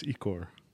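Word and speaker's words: ichor: It's Icor.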